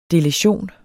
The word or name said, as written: deletion